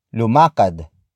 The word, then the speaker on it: lumakad: Lumakad ako. Lumakad ako. Lumakad ako. It means I walk or I walked depending on the context. Word for word it's lumakad, ako.